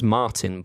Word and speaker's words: Marton: It's spelt weird. Pronounced Martin, but it's spelt Marton.